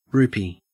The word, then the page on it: rupee: Australian English